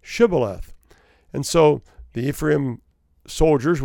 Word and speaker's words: shibboleth: So there was a word, shibboleth, that the two groups of people pronounced differently. In Ephraim, they could not pronounce the SH. Okay, it became S. The Gilead guys, they could say shibboleth. The Ephraim guys couldn't. They said sibboleth.